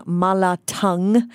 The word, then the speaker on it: mala tang: Michelle recommending mala tang. That is how you pronounce mala, T-A-N-G, which refers to a spicy, numbing, hot soup.